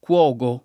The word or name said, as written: Cuogo